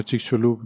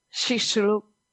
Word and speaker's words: Chicxulub: Como una SH decía, como Chicxulub, ¿no? Sí. – Chicxulub.